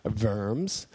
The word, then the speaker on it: Worms: And when that happened, then they finally did get him to come to a place called Worms, which is funny. Or in German, they pronounce it Worms.